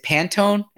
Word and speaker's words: Pantone: Is it Pantone?